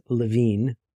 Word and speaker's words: Lewin: And if you look him up, it's not L-E-V-I-N, it's L-E-W-I-N. He's a German-American, so his name is pronounced, actually it's Levine, looks like Lewin.